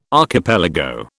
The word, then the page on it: archipelago: British English